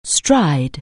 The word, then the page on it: stride: anglais